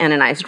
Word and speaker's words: Ananias: And it's Ananias, not Ananias. It rhymes with bananas.